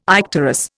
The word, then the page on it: icterus: Indian